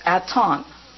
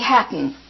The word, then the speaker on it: Aton: In symbolic language, that's H-A-T-O-N-N. The first symbol, which you call an H, is silent. The last N, which you call a little humpbacked symbol, is also silent. But it is so that you will not call me Hatton, H-A-T-T-O-N. I am not Hatton. My name is Yorgos, G-Y-E-O-R-G-O-S, Ceres, C-E-R-E-S, Hatton or Aton. Put an apostrophe, A-T-O-N, apostrophe.